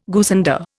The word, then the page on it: goosander: Indian